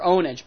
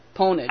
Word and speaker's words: Pwnage: They actually pronounce it Pure Ownage, but it's, I always say Pwned. Pwnage.